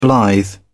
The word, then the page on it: blithe: British English